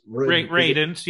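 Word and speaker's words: Radunz: Is that pronouncing his name? It's Radins. Yeah.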